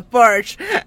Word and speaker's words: Porsche: Eso, la pronunciación correcta, Porsche.